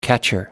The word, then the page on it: catcher: American English